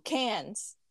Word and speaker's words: Cannes: I think is it the Cannes or the Cannes Film Festival? – Oh, Cannes.